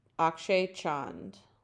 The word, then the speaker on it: Akshay Chand: Akshay Chand. Okay. – How do you spell that? – His first name is Akshay, so A-K-S-H-A-Y. – Okay. – Chand, C-H-A-N-D. – Oh, okay. Just like it sounds.